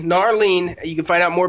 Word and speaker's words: Gnarlene: Sean, I don't know how to say this. G-N-A-R-L-E-N-E. – Narlene? – The Narlene song? – Yeah, the Narlene song.